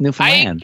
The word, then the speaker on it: Newfoundland: Newfoundland. Understand. Newfoundland. – Don't get nervous when you say it. Just Newfoundland.